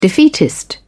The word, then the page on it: defeatist: British English